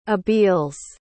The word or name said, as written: abeles